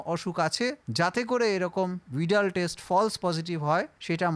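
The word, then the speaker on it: Widal: UIDAL কিন্তু উচ্চারণটা ভিডাল টেস্ট।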